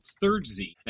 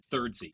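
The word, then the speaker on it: Thirdzy: It's called Third Z. And so the way that you would spell that is third with a ZY on the end, Third Z.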